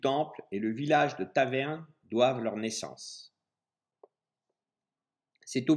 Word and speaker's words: Taverne: Au fil du temps, le mot latin Tabernae a donné naissance au toponyme taverne. Contrairement à la prononciation habituelle, l'accentuation des toponymes similaires en allemand se fait sur la deuxième syllabe du nom, ce qui indique encore aujourd'hui l'origine romaine de notre localité.